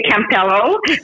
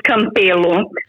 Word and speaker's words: Campelo: Well, if you try to pronounce it in English, I would say Campiello. In Portuguese, it's Campelo. So it sounds a bit different, but it doesn't really matter.